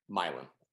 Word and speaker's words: Milan: also, my wife's from Milan, Kansas. Milan. – Not Milan.